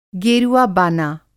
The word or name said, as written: गेरुआ-बाना